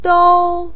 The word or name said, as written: dou1